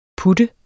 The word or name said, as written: putter